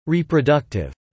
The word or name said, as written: reproductive